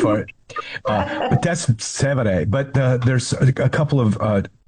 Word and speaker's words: Trastevere: Yeah, Trastevere.